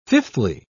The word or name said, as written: fifthly